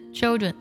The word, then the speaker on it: children: 第一个单词 children，我们读的时候要特别注意，不要读作秋，不是秋，是 chill，它是一个舌侧音重音， children 要这样去读。